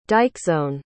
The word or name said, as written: dikesone